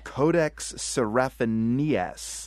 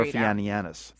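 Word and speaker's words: Codex Seraphinianus: Codex Serafinianus. – The Codex Serafinianus. – No. Serafinianus. – Serafinianus. – Throw in an extra syllable just for the hell of it.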